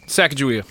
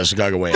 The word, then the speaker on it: Sacagawea: Hold on, let me say it right. Oh, God damn it. All right, I'm going to Americanify it. Sacagawea. – Yeah, Sacagawea. – That's it!